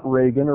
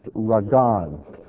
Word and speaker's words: ragan: It's the word ragan or rogan. It's not Reagan or Rogaine. It's just ragan.